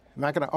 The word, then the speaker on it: Mackinac: She claims that there are environmental concerns where it crosses the Straits of Mackinac. I believe I pronounced that right. Biden is weighing his options. The consequences would be huge. – Mackinac.